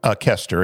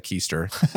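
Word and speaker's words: Koester: Kester. It's Craig Kester. – I didn't want to say Keister. – Well, you could, say Craig Keister, but it's K-O-E-S-T-E-R.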